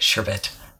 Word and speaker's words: sherbet: I keep doing two R's in it. Sherbet.